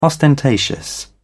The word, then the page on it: ostentatious: British English